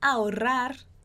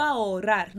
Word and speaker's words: ahorrar: Como é que a gente vai fazer a pronúncia dessa frase? A o Ra no consigo, no consigo ahorrar, no consigo ahorrar. A o rar, ahorrar tanto.